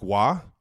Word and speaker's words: Roy: I got Patrick Waugh, also known as Patrick Roy in the Kelce household, because dad only pronounces things one way, his way, a la our last name.